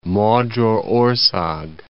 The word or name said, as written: Magyarorszag